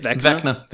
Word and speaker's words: Vecna: like the Eye and Hand of Veshna. I'm assuming I'm saying that right. I know that's like a really... – Isn't it Vecna? – Vecna. – Why did I think it was Veshna?